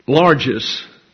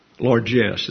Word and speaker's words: largesse: The exchange of money for largesse doesn't promote an economy. Largesse is the correct pronunciation. Largesse. Sounds French.